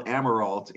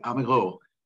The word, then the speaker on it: Amirault: Um, I, am in the US and uh, my name is Bill Amirault in English, but when my father came over from Nova Scotia, that's how they pronounced it, Amiro, like you did.